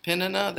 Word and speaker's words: Peninnah: Peninnah. – That I heard a guy that knows Hebrew well, and the first part was Penny, like Penny Wilson at the yeah.